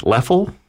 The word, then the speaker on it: Leffel: Local artist Heath Leffel. Mm-hmm. Am I saying that correctly? – Yep, tattoo artist extraordinaire.